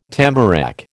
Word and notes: tamarack: American English

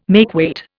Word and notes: makeweight: Indian

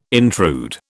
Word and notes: intrude: British English

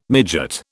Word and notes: midgut: British English

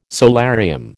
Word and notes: solarium: American English

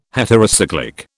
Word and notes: heterocyclic: British English

